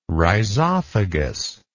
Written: rhizophagous

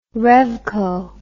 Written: revco

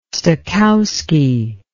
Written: stokowski

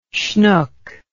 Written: shnook

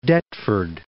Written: Deptford

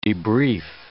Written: debrief

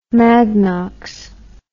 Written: magnox

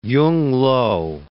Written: Yung-lo

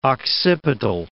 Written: occipital

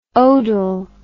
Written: odel

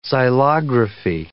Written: xylography

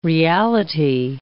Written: reality

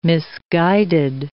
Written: misguided

